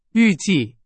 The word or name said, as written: 预计